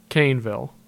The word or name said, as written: Kaneville